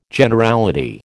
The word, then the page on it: Generality: American English